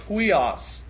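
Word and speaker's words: huios (υἱός): And the Greek word that Paul actually uses here is pronounced huios.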